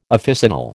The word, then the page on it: officinal: American English